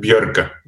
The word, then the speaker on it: Björkö: Björkö, how do you pronounce it? – I said Björkö. – Björkö? – Yeah. – Okay, awesome.